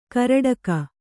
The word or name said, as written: ಕೇರಡಿಕೆ